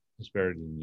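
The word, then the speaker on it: hesperidin: hesperidine, how do you pronounce it? Hesperidine? – Hesperidine, yeah.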